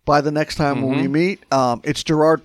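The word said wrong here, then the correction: Gerad: Parker. – I think it's pronounced Jared. – Jared Parker.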